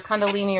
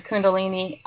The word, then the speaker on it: Kundalini: You actually hear it pronounced either Kundalini or Kundalini, different.